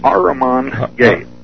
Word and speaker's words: Ahriman: The Ahriman Gate. – Ahriman Gate, okay. – Yeah, it's spelled Ahriman.